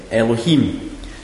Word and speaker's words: Elohim: And it's just E-L-O-H-I-M. Elohim.